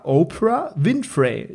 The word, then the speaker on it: Oprah Winfrey: Das war Opera, Oprah Winfrey. Ich hoffe, ich habe es richtig ausgesprochen.